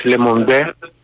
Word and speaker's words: Le Monde: Είναι χαρακτηριστικό ότι σύμφωνα με δημοσίευμα της γαλλικής εφημερίδας Le Monde, το είπα καλά με προφορά? – Πολύ καλά το πες.